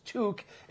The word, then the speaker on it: toque: You can call them beanies, knit caps, and caps, but the proper pronunciation is toque. It rhymes with Luke.